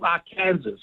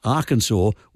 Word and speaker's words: Arkansas: So Kansas is pronounced Kansas, but the one next door, Arkansas, is pronounced Arkansas.